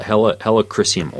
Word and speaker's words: helichrysum: It's spelled H-E-L-I, like helicopter, and then chrysium, C-H-R-Y-S-I-U-M. But helichrysum oil works really well, especially for scars.